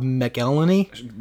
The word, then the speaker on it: McElhenney: That's not Always Sunny from, I can never say his name. Rob McElhenney. – Nailed it. Sure.